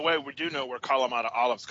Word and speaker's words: Kalamata: and Kalamata olives. Is that how you would Kalamata.